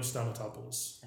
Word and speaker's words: Stamatopoulos: Dino Stamatopoulos. – That's the one. – Little Christian Adventures. – Alex quotes Starburns on Community, if you were watching Community. – Oh, okay. – That's Dino Stamatopoulos.